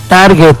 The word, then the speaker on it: target: No, es target. En inglés se pronuncia target.